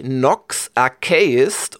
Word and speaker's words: Nox Archaist: Äh, es spricht sich Nox Archaist und es schreibt sich N-O-X A-R-C-H, also wie in Archmage und hinten noch Aist, also A-I-S-T.